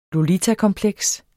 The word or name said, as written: lolitakompleks